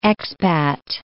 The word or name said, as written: expat